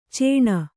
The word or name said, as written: ಚೀಣ